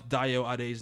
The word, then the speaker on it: Dayo Ade: It's pronounced phonetically Da-Yo-A-De, and both first and last names are shortened for the English tongue to pronounce.